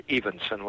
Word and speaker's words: Evenson: Actually, my name is Evenson with all long E's.